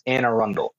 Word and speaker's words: Anne Arundel: It is pronounced Anne Arundel.